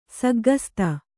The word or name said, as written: ಸಗ್ಗಸ್ತೆ